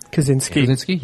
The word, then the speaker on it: Kosinski: Kosinski.